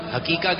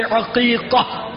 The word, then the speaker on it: عقیقہ: اچھا امیر السنت کی بارگاہ میں عرض ہے, کچھ لوگ حقیقہ کہتے ہیں, حقیقہ اس کا صحیح تلفظ کیا ہے؟ – عین سے, عین سے عقیقہ.